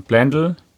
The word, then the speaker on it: Blendle: Du kommst ja von Blendl hierher. – Schön ausgesprochen, ja. – Ja, das habe ich mir direkt gemerkt. Ja, es heißt nämlich Blendl und nicht Blendle.